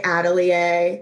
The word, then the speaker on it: atelier: Like some people say atelier, that's like the French way.